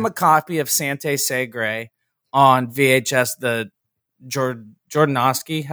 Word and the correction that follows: Jodorowsky: How do you say it, Hessa? – Jodorowsky? – Yeah, whatever the hell.